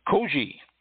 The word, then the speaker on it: koji: Koji. K-O-J-I with a long O.